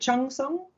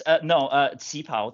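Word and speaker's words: qipao: Is it Chang Song? Chang Song? – No, it's Cipao. Cipao.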